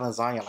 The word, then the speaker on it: lasagna: The G in lasagna is silent.